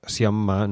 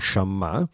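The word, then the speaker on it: Sciamma: Il, se l'insiamma, tra l'altro un nostro ascoltatore ci ha fatto notare che ho sbagliato a pronunciare il nome eh, per tutta la puntata, il, si pronuncia Siamma, non Shamma.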